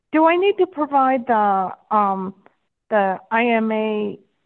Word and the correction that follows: IMEI: I can never say that right, IMEI.